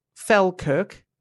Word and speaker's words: Falkirk: I said Falkirk, F-A-L-K-I-R-K, Falkirk.